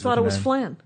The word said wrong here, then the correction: Flam: I always thought it was Flan. And then I thought, is it pronounced Flan? But it isn't because it's Flam. – It's Flam. – With an M.